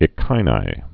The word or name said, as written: echini